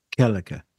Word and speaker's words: Gallagher: Did I pronounce his name right? – Gallagher. The K.